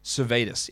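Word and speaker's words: Sirvydis: Davidas Savitas. Davidas Cervetus. Yeah, that's right. That's, I pronounced it right.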